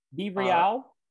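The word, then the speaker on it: BeReal: Yeah, I am now on Be Real. – Be Real. – I like to pronounce it like it rhymes with cereal.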